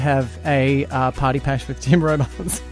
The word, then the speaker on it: Tim Robards: Tim Robards is going to be on there as well. Is that how to say the Bachelor's name? Yeah, they just announced it today.